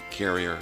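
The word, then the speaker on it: Carriere: And Gabby, did I say that right? Carrier? – You did.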